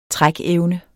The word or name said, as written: trækevne